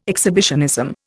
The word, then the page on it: exhibitionism: Indian